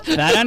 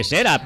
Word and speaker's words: Zara: Zara no se dice Sera, pero tonta lo hemos aprendido todos a pronunciar.